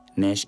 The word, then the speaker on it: Nash: And yeah, if you want to catch me on my own personal Facebook page, Nash Workaholic Bobo, Nash N-A-S-H Workaholic, you know how to spell it, Bobo as in B-O-B-O.